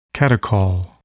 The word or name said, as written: catechol